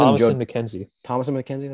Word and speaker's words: Thomasin McKenzie: She was Thomasin McKenzie.